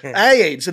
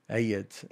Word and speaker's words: AYDS: It's two syllables. Yeah. It's four letters. AIDS. – AIDS.